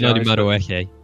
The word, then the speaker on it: Madueke: I would wish that we would just delve deep and buy someone like Noni Manduki. I've probably butchered his name, so I apologize. – Noni Madueke.